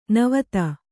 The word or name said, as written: ನಿವಾತ